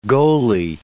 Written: goalie

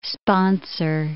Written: sponsor

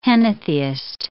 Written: henotheist

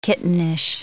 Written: kittenish

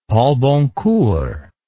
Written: Paul-Boncour

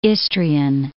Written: Istrian Peninsula

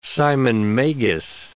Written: Simon Magus

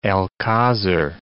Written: alcazar

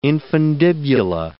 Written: infundibula

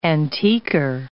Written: antiquer

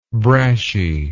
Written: brashy

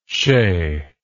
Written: chay

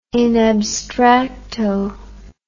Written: in abstracto

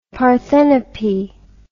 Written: Parthenope

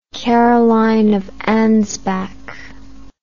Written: Caroline of Ansbach

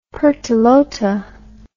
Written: Pertelote

